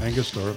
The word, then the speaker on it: Angostura: Angostura bitters.